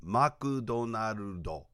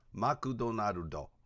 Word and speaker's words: マクドナルド: Okay, it's not McDonald's, it's Makudonarudo. Makudonarudo.